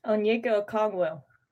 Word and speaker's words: Onyeka Okongwu: Onyeka Okongwu.